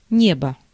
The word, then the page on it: ciel: France; IPA: [sjɛl]; Paris